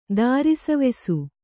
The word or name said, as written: ದಾರಿಸವೆಸು